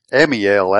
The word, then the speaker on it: Amiel: And Ziba says to the king, behold, he's in the house of Machir, M-A-C-H-I-R, the son of Amiel, Amiel actually is how you say that, and Lodabar.